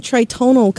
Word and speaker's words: Tritonal: Tell me, where did the name Tritonal come from? And am I saying that right? – You are, which is great, because a lot of people don't say it right.